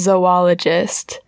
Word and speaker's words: zoologist: and my teacher was very adamant that it's pronounced zoologist, because it's like the Z and the O, you just take the first O, and then the rest, the other O is devoted to ologist,